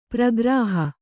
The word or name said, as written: ಪ್ರಗ್ರಹ